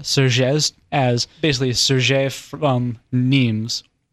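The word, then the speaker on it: Nîmes: It was a town called Nimes, spelled N-I-M-E-S, like dimes, but pronounced Nimes. And they started to produce a fabric called Serger, S-E-R-G-E-S.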